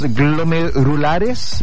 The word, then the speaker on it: glomerulares: Y adicionalmente, lo que dice la veterinaria y lo que detalla es que hay enfermedades glomerulares específicas, digamos. Lo dije bien pelado, ¿no? O me estoy tirando al carril nomás. ¿Ah? Se dice así, las glomerulares, ya.